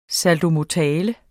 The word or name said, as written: saltomortale